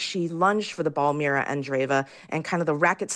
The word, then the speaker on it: Andreeva: And what about, in the just minute we have left, this 16-year-old Russian, Mira Andreeva? Am I saying that right? – Yes, you are. Yeah, Mira Andreeva.